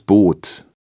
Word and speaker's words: Boot: Wenn man ein Wort mit Doppel-O schreibt oder wenn ein H nach dem O steht, dann spricht man es immer lang und gedehnt aus. – Das Boot.